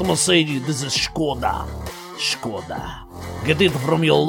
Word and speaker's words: Skoda: Skoda. That is how it's pronounced. Skoda, not Skoda.